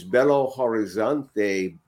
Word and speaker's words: Belo Horizonte: Thaddy is from BH, which is Belo Horizonte, Brazil. Did I pronounce that right? Even close? – Yeah, yes, yes, yes.